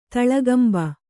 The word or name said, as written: ತಳಗಂಬ